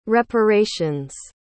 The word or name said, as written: reparations